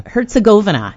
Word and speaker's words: Herzegovina: Against Bosnia and Herzegovina. That's how you want to pronounce it, right? – Herzegovina is how I say it.